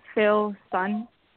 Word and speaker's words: Philson: Philson. – Philson? – Yeah, Phil like Philip, son like the sun.